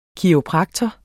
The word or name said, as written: kiropraktor